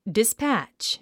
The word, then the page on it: dispatch: dispǽtʃ; ディスパァッチ